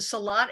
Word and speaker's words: Salat: What's it, Salat, S-A-L-A-T, is that how you spell it? – Yeah.